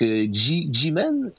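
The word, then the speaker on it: Ximen: Bien, ça, c'est dans le quartier, je sais pas comment le prononcer, là, Jimen. C'est un X, là, c'est comme à la fois un X et un chi, là, donc Jimen.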